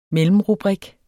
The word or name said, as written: mellemrubrik